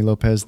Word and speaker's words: Lopes: I always say like Slopes, you wouldn't say Slopes. Cantaloupes, you wouldn't say Cantaloupes.